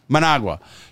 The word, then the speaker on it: Managua: Managua.